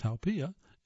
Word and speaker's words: haupia: This pudding, pronounced haupia, is as thick as lava.